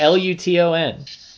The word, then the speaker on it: Luton: Lunta. – It's not Jake Lunta. – It's Lunta. – It's not Hecky Lunta's cousin, dude, Jake Lunta. Lunta. It's not Lunta. It's Luton.